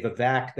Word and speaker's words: Vivek: My name is Vivek. That's the correct way to pronounce it. People say Vivek. They say other things.